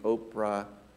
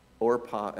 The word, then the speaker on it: Orpah: Somebody started mispronouncing Oprah Orpah as Oprah, and it just stuck.